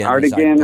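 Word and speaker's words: D'Artagnan: Hey, and that that company we're talking about, I was talking about Dr. Tiarton. Dartigan. – Dartigan, or whatever that is. Dartigan, as I believe Dartigan. how it's pronounced, yeah.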